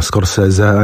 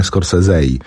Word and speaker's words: Scorsese: Tak się podobno wymawia. Scorsese, a nie Scorsese.